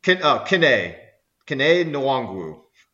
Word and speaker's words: Kene Nwangwu: Nene Nwangwu. – Kene Nwangwu. I didn't even pronounce it right.